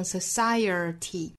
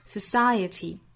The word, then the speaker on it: society: 我们再往前看 society 这个词，这个词发音呢不管音还是美，单词内部是不会有 er 的发音的，不能发成 society，应该是 society，中间包含的是一个 schwa sound，不要把它误会成是 er 的发音 society society。